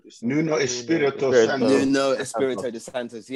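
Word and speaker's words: Nuno Espírito Santo: Nuno Espirito Santos.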